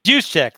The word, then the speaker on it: Juszczyk: Kyle Juszczyk. – Juszczyk, that's it. You're still living in Pittsburgh, so you're up to date on all the Polish pronunciations.